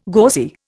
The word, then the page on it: gauzy: Indian